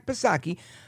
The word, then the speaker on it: Psaki: But when asked about it, the White House press secretary, Karine Jean-Pierre, now she's no circleback Psaki, silent P, of course,